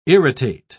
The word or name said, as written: irritate